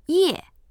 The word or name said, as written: ye4